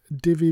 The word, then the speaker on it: Divi: one of their themes like Divi, D-I-V-I. I keep saying Divi, D-I-V-I. It's pronounced Divi, but, you know, just to make that clear.